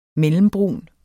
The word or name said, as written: mellembrun